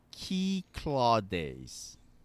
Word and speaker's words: Cyclades: Halfway through most recent episode, but just because it was said a lot on this pod, wanted to let y'all know the Kith V2 is pronounced like Key Claw Days. What is that? Key Claw Days. – That's the Cyclades, as I say it. Key Claudes, I guess. It's a place in Greece.